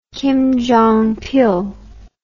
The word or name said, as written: kim jong pil